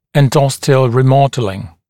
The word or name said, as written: endosteal remodelling